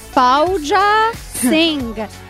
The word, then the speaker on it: Fauja Singh: Fauja Singh. – I think that was pretty good. Fauja Singh میگی؟ – Punjabi.